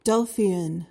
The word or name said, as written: delphian